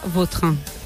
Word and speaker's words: Vautrin: La original francesa, Vautrin.